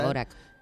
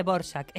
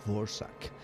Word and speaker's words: Dvořák: Eso, escrito Dvořák, pero se dice Dvořák, es el mismo compositor. – Se escribe Dvořák, sin, o sea, D-V-O-R-A-K, y se pronuncia Dvořák.